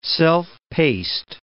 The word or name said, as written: self-paced